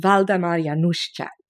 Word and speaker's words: Waldemar Januszczak: Waldemar Januszczak.